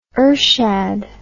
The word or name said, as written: ershad